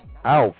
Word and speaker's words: elf: T-I-E-N. Team. Like teen spirit. Teen is the number 10. Teen. T-I-E-N. Teen. Moving on. Elf. Alf. Like said Alf. A-L-F. But it's E-L-F. Alf. 11. Alf. Alf. You know the E is pronounced A. So Alf. Alf. – Alf. – Yeah. – Alf. – Alf. 11. Alf. 12. T-W-A-A-L-F.